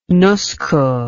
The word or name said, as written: Nusku